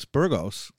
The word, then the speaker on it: Burgos: Today we have a community leader and activist, Alex Burgos.